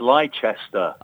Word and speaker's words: Leicester: Most importantly, he knows how to pronounce Leicester. We welcome back to the pod, Arlo White. – Hello, chaps. It's Leicester.